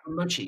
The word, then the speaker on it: mochi: Did I say it wrong again? Oh, from Mochi Mochi Boy. Mochi, yes.